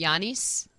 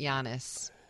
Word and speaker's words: Giannis: I would have pronounced it Giannis, but he pronounces it Giannis, which means which is translated to John, believe it or not.